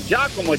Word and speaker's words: Giacomo: You pronounced it right. Giacomo, G-I-A-C-O-M-O.